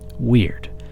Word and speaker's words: wyrd: Weird, pronounced like the English word weird, but spelled W-Y-R-D, is a Norse and Anglo-Saxon word.